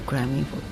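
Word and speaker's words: Crime Info: まあ、クライムインフォってアルファベットでね、クライム、 CRIME、まあ、犯罪のクライムにその後インフォ、インフォメーションの情報のインフォということで INFO と書いてクライムインフォっていうんですけども、はい、これはもともと過去 2 年間、欧州委員会という EU の機関から助成金を受けてやってきたプロジェクトなんですね。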